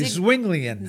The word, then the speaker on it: Zwinglian: Zwinglian.